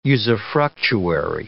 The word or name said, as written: usufructuary